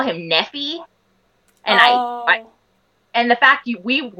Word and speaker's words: Nephi: And I was just like, no, honey, it's Nephi. If you're going to take names from something, look at the pronunciation.